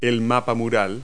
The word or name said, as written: wall map